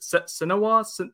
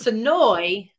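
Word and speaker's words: Senoia: How do you pronounce where you guys are from? Sinoa, Georgia? – It's Sinoy. – Sinoy. – Almost like there's two E's on the end instead of the IA.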